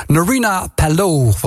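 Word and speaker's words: Nerina Pallot: Narina Pallot, want ik heb van Siet geleerd dat ik het zo moet uitspreken.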